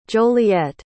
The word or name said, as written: joliet